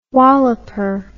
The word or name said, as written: walloper